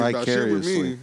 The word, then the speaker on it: vicariously: No, you ain't curious vicariously. – About shit with me. – I'm vicariously. – Is that the proper way to say it? – Yes. Vicariously. – I thought it was bicariously.